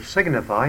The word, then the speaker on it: signify: Get used to using the word signify in connection with the noun sign. You just pronounce the G, signify.